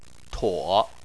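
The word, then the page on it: tuo: tone 3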